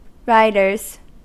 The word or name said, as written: riders